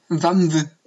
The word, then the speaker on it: WAMMW: A M M W heißt das Lied. W A M M W. Wamw. – Also w A M M W. Wamw. – W. Ah, okay. – W-A-M-M-W,